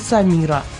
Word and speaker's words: Самира: А самые популярные женские имена недели – Анастасия, Мирослава и Самира. Надеюсь, что я правильно поставила ударение. – Правильно, это боснийское имя.